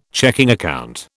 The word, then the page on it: checking account: British English